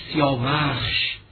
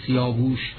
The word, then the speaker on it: سیاوخش: سیاوش جوانی سیاوخش، با هیچ تعبیری اساسا تلفظ سیاوش درست نیست.